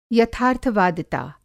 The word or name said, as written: यथार्थ-वादिता